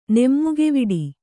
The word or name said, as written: ನೆಮ್ಮುಗೆವಿಡಿ